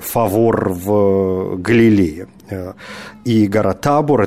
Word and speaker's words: Табор: Ну, поскольку речь идет о Чехии, то я не уверен с ударением, но, видимо, если речь идет о Чехии, то все-таки Табор должна она называться, а не Табор.